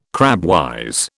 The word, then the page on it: crabwise: British English